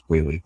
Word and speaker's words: Whealy: Our last name is pronounced Whaley, but spelled W-H-E-A-L-Y. Or T-L-Y a lot of the time, A-L-E-Y, whatever. Pronounced Whaley a lot, Wheatley or Whalen or whatever, but it's actually pronounced Whaley, E-A-L-Y, but it used to be A-L-E-Y, which is where the pronunciation came from.